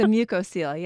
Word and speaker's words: mucocele: It's a mucocele, yeah. So that's something we learn about. It's actually something inside the mouth. And mucoceles they're kind of neat when you see them.